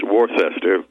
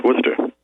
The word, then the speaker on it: Worcester: Everybody who's not from the state thinks it's pronounced Worcester, but those of us in the know that grew up there know it's Worcester.